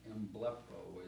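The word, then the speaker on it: Emblepo (ἐμβλέπω): Emblepo is how you pronounce it.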